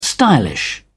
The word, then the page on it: stylish: British English